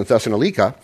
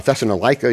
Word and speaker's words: Thessalonica: No other church did this, even when I was in Thessalonica. Uh, Thessalonica, either way, people pronounce it both ways.